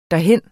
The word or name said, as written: derhen